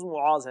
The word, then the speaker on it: معاذ: معاذ یا معاذ؟ – نہیں معاذ, اصل تلفظ معاذ ہے, میم پر پیش ہے.